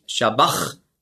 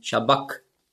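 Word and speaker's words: sabachthani: Und das CH im Griechischen, das ist ja ein Buchstabe, das Chi, das hat man ausgesprochen so wie ein C hinten im Gaumen. K. Also nicht Shabbach, sondern Shabbak.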